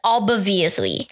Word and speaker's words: obviously: 但是我讲的是 obviously. – Obviously. – 所以我不知道怎么发这个字 like in a natural way. 然后、 yeah. – until you say, okay, don't pronounce the B sound.